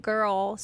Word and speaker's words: Grrrl: So I know we're saying Riot Girl, so you probably think it's spelt regular, but it's actually spelt G-R-R-R-L.